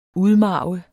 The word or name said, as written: udmarve